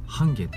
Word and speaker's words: 半夏: 例えば半夏っていうねあの半分の半に夏って書いて半夏って読むん